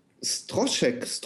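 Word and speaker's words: Stroszek: Ich habe noch einen Stroschek wird es ausgesprochen.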